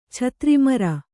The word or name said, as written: ಛತ್ರಿಮರ